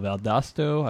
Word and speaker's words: Valdosta: I don't know if I'm saying it correct, but Valdosta, which is where DL Hall, Orioles' new draft pick, it went to high school, Valdosta, I believe I'm saying it right. – You're absolutely right.